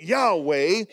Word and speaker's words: Yahweh: And the YHWH, or we pronounce it Yahweh, our God, is the potter.